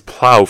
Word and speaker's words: plough: And then it's plow, for example. Plow is PLOUGH. GH, again, you don't pronounce the GH.